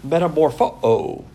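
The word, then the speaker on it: metamorphoo: The Greek word metamorpho-o, when you get two O's, it's pronounced with a uh-o.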